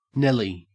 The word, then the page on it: nelly: Australian English